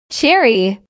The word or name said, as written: cherry